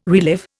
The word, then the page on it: relive: Indian